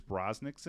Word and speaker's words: Brosnan: Pierce Brosnan's in it. – Bronson. – I never pronounce his name right.